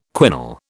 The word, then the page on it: quinol: British English